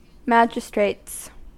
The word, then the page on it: magistrates: American English